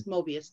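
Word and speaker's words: Möbius: It's a vampire series, and he talks about this man who's like a vampire hunter or whatever, and he travels through I would never say it right, Mobius time, Mobius, where it's the strip that goes back like Mobius. – And it's all one side. Yes, Mobius, thank you.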